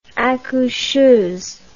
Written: accoucheuse

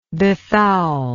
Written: befoulment

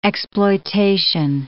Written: exploitation

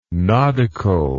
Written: nodical

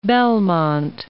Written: Belmont

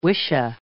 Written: wisha

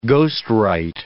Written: ghostwrite

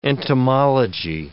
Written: entomology

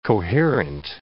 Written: coherent

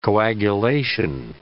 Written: coagulation

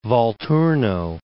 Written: Volturno

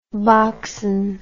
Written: boxen

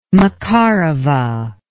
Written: Makarova

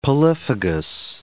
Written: polyphagous

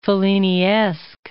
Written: Felliniesque

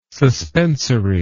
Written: suspensory

